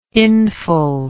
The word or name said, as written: hindfell